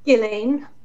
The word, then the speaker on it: Ghislaine: How did you pronounce her first name? – Ghislaine. – Ghislaine. Okay, thank you. I see names like that, and I'm like, oh, darn. – And I do hear some people pronouncing it differently, but from what I gather, that's how it's supposed to be pronounced.